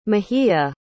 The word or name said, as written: mejia